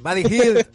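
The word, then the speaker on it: Buddy Hield: Chris Paul, Austin Rivers, a ver si lo digo bien ahora, Buddy Hill. – Muy bien, Buddy Hill.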